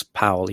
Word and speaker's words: Powell: Charles Powell, he actually pronounces his name Powell, was Thatcher's consigliere. – But his brother is Powell, yeah, and he advised Tony Blair.